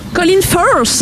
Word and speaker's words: Colin Firth: Colin Firth.